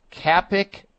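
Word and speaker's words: Kapikachu: There's also another herb that Purdue University, I remember reading about, Purdue University studied, and that herb is pronounced Capikachu, K-A-P-I-K-A-C-H-U, K-A-P-I-K-A-C-H-U. And it's also called cowhage, C-O-W-H-A-G-E, same herb, just a different name.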